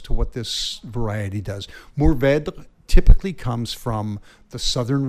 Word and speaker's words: Mourvèdre: Mourvèdre. But you've heard of that one. – I have, and I love it. Mourvèdre, as the French pronounce it, is a sensational red grape.